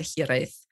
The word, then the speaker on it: hiraeth: But anyway, that's a great phrase, kissing through a handkerchief, to get at hiraeth, which you pronounce beautifully.